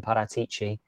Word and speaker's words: Paratici: They've lost their managing director of football in Paratici. – They've lost lovely pronunciation there.